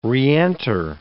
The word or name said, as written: reenter